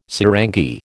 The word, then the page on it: sarangi: American English